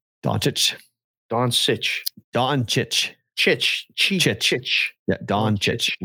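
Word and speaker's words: Dončić: What do they say? They say Doncic, Doncic, Sitch with an S. Sitch. It's Chich.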